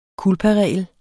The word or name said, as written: culparegel